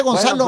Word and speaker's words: Gonzalo: Ah, sí se dice Gonzalo. Bueno, pues, el compa Gonzalo. – Sí, porque es una C y luego como una colita. – Sí, como una. Ándale. Ajá. Gonzalo Ramos.